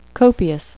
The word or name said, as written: copious